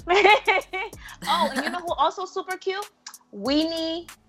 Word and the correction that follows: Winnie Harlow: Oh, am I saying her name? – Winnie Harlow.